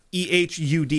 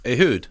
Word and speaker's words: Ehud: The planning of this side mission fell to, I'm going to butcher this guy's name and I'm going to apologize now, Ehud Barak. – He said it's kind of fine. – It's Ehud is his first name. – Ehud. – Yeah, so close enough.